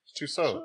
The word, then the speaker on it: Tussaud: Tussaud. – That's why Tussaud is the way it's pronounced. – Check it. Tussaud. I don't know. It's French.